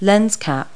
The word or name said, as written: lens cap